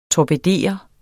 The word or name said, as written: torpedere